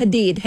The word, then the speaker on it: Hadid: For controversial almond moms like supermodel turned reality star Yolanda, how do you say her last name? – Hadid.